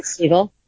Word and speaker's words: Segel: Segel.